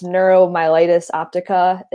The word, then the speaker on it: neuromyelitis optica: It's neuromyelitis optica.